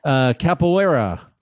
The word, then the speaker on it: capoeira: Okay, now we're going to talk about the thing on your website that is not usual for travel sites, and that's your Brazilian capoeira. – Yes. – Did I say that right? – You did, yes.